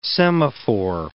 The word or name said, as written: semaphore